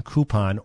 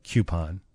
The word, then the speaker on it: coupon: This one suggests, well, this one says both, K-O-O-P-O-N, coupon, or K-Y-O-O-P-O-N, coupon. I think that one they're saying either is correct.